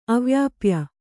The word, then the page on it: ಅವ್ಯಾಪ್ಯ: avyāpya